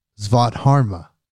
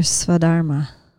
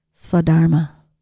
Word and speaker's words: Svadharma: My podcast is my Svadharma. Is that the right word? Did I say that right? – No. It's your Svadharma. Svadharma.